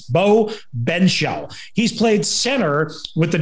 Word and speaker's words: Benzschaul: So I'm going to do the best we can and say it's Bo Benshaw. Bo Benshaw, yeah, is how I believe, and I remember his name being pronounced. Benshaw, spelled Benzschaul.